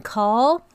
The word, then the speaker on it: Kahl: All right, so I'm pronouncing his name as in Kahl. – Okay. – Because it's Gordon Kahl, K-A-H-L. – I would call it Kahl. – Right, like Doll.